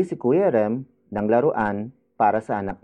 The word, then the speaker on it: Kuya: Bumili si Kuya Rem ng laruan para sa anak niya.